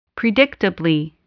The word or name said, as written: predictably